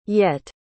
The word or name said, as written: yett